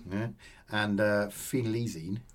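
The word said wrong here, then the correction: phenelzine: No, phenylzine.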